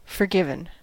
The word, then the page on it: forgiven: American English